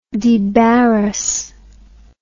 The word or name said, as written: debarrass